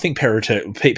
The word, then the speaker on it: Perrottet: I think Perrottet, people will let you get away with Perrottet.